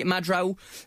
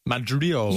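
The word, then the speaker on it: Mandroiu: Brilliant goals from Danny Madrou, if I can pronounce that name correctly. I always get that wrong. Danny Madrou. – Yes. – Yeah. – I think it's Mandrew. I think that's spelled wrong on live score.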